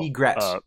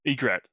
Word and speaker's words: Egret: You can do a greet if you wanted to import that in as well. – Egret. – Egret. I always say that wrong.